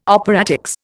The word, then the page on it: operatics: Indian